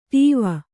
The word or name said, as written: ಟಾವು